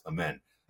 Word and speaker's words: Amen: It's spelled Amen, but it's pronounced Amen.